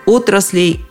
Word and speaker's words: отраслей: Точно так же, как в единственном числе, ударение во всех падежах на начальное «о». Отрасли, отраслей, отраслям, отраслями, об отраслях.